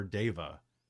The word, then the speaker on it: deva: Player's Handbook 2 was half-orc, shifter, gnome, Goliath, and diva or deva, even though it's spelled diva.